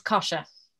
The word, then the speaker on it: Kosha: It's Kosha.